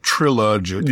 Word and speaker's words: Trilogic: And so the deal is that Stephen and Dodo must finish a series of puzzles before the Doctor completes this 1,023 move Trilogic game. – and it turns out this, I think the Toymaker pronounces it Trilogic. – Yeah. – like trilogy, but it actually is just the Towers of Hanoi puzzle, which, it's pretty well known old puzzle, and it is not.